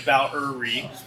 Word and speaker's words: Valerie: My mom's name is Valerie. That's with three syllables. She always makes sure I pronounce that right. Valerie.